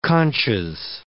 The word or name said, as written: conches